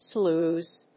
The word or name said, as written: slews